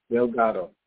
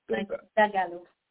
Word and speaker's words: Dagalo: Delgado. – Dagalo. – Dagalo. Dagalo. I have a supervisor named Dagalo.